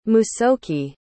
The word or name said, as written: musoke